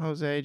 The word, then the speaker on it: Jose: The legend deserves his name pronounced correctly. – Jose.